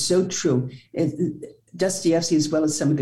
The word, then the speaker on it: Dostoevsky: Dostoevsky, who, you know, Crime and Punishment.